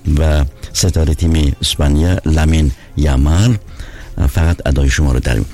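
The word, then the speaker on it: Yamal: فامیلش رو به جای یامال جمال تلفظ میکنن، جمال.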